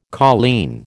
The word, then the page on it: colleen: American English